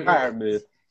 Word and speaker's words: Freiermuth: Freiermuth? You know? – Freiermuth.